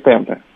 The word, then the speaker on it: темпе: Я правильно назвал вот темпы или темпе? – Правильно сказать, темпы.